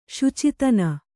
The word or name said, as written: ಶುಚಿತನ